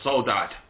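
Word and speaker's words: Sodade: And there's another one called, now forgive me if I pronounce this wrong, yeah? Cesaria Evora. And the song's called Soldade or Soldad.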